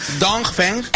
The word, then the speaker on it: Dongfeng: Dongfeng. – XPeng y Dongfeng. – Dongfeng. – Es que si pronuncias mucho la G, al final lo liamos.